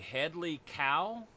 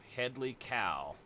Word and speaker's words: Hedley Kow: Perhaps the Hedley Kow.